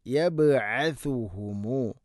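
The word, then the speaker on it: يبعثهم: അടുത്ത ആയത്ത് യൗമ യബ്അസുഹുമുല്ലാഹു ജമീഅ യൗമ യബ്അസുഹു യബ്അസുഹുമു. ഇവിടെ ശ്രദ്ധിക്കേണ്ടത് സ എന്ന അക്ഷരം, അതിൻ്റെ ഉച്ചാരണം സീനാവരുത്. യബ്അസു അല്ല യബ്അസു. അതുപോലെ തന്നെ സഅന് ദമ്മാണ്. ഇത് രണ്ടും കൃത്യമായിട്ട് ശ്രദ്ധിക്കണം. യബ്അസുഹുമുല്ലാഹു യബ്അസുഹു യൗമ യബ്അസുഹുമുല്ലാഹു ജമീഅ.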